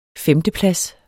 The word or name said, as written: femteplads